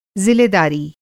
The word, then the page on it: ज़िलेदारी: ضلع داری